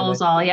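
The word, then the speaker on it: Dolezal: Obviously, Dolenzall, I think. Is that how you pronounce it? Yeah, Dolenzall, yep.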